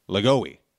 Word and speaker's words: Lajoie: Hall of Fame baseball player Nap LaJoy pronounces his last name Lagoey. So I guess it's Nap Lagoey.